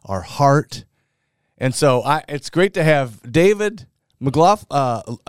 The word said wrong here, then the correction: Laughlin: No Mick, just Laughlin. – Laughlin.